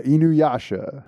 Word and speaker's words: Inuyasha: So you went into the world of anime and we're going to talk, I'm going to say this right, Inuyasha. – Correct.